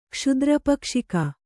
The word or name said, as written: ಕ್ಷುದ್ರಪಕ್ಷಿಕ